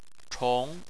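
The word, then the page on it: chong2: tone 2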